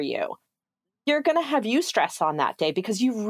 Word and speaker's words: eustress: It's not spelled at all the way that it sounds. It's pronounced eustress, as in like eustress, which is hilarious, but it's spelled with an A.